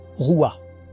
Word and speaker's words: rouah: Et c'est important car le mot utilisé est « roua », R-O-U-A-H. Le H en hébreu se prononce comme une jota aspirée, roua, qui signifie « esprit ».